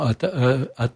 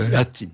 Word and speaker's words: Hattin: Alors on prononce comment Hattin. – Hattin, c'est ça.